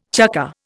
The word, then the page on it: chukker: Indian